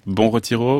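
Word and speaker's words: Bom Retiro: Alors, je vais essayer de les prononcer. Bon Retiro, Brasse, Paris, je prononce correctement ? – Oui.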